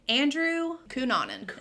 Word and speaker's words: Andrew Cunanan: Okay, so this week I decided to, like I said earlier, Andrew Cunanan is how it's pronounced.